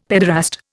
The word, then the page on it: paederast: Indian